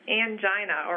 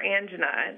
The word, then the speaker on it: angina: They're also less likely to have angina or angina. It depends on which school you went to, how you pronounce it.